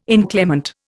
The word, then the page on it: inclement: Indian